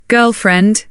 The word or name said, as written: girlfriend